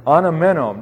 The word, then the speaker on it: anamenō: Ana, A-N-A-M-E-N-O. And of course, with all Greek verbs here, the last O, or the O at the end, is the omega, the long O there. Anamenō.